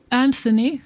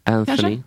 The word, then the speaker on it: Anthony: Kan du uttala åt mig, snälla? – Anthony. – Anthony, så är jag rätt där? Eller var det F? – Det lät jättebra.